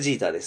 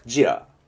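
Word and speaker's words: Jeter: 日本語ではデレック・ジーターです。英語では、なんて言うんだ、ジーターではなくて、デレック・ジラ。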